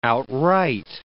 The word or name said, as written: outright